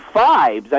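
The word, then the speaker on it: Phibes: The abominable Dr. Fibs? I think it rhymes with vibes.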